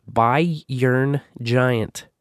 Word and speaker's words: BayernGiant: I pronounce it Bayern Giant. So it rhymes with I yearn.